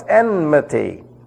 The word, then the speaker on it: enmity: Verse 15, and I will put enmity, this is one of those rare English words where the N precedes the M. Most people pronounce that enmity. No, it's enmity.